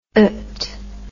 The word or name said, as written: ite